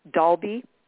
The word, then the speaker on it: Dalby: But people can go to your website, which is Lainie, Lainie, Love, Dalby.